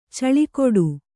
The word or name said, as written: ಛಲಗೇಡಿ